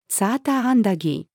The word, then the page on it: サーターアンダギー: female